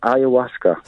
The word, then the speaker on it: ayahuasca: But that's just to really tell you what the word exactly was, ayahuasca.